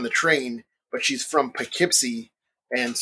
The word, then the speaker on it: Poughkeepsie: This is a town that I mostly associate with an episode of Bewitched, where the Stevens neighbor, Mrs. Kravitz, keeps trying to say Poughkeepsie, and she pronounces it Kapoopsie.